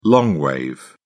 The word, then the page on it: long wave: British English